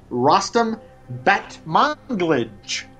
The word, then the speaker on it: Rostam Batmanglij: And then I will try to pronounce the name. Discovery, of course, is the effort of Ra Ra Riot's Wes Miles and Vampire Weekend's Rostam Batmanglij. What do you think? – Very good.